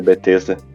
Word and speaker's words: Bethesda: Não sei como é que fala o nome da empresa. É, da Bethesda.